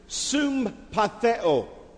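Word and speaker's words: sympatheo (συμπαθέω): The word sympathize, in the Greek, we pronounce it sympatheo. Even sounds like sympathize.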